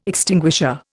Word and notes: extinguisher: Indian

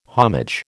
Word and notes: homage: American English

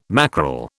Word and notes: mackerel: British English

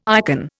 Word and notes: ikon: Indian

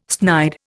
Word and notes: snide: Indian

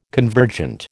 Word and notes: convergent: American English

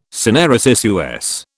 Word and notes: syneresis: American English

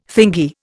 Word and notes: thingy: Indian

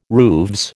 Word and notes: rooves: American English

